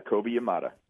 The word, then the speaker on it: Kobe Yamada: I hope I'm pronouncing his name correctly. Kobe Yamada? – Yeah, Kobe Yamada.